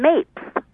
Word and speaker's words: Mapes: Well, it's actually Mary Jane Mapes. – What is wrong with me? What am I, looking at this phonetically? – Well, it could look like maps, but the E in there sort of change. I always tell people it's like apes with an M in front of it.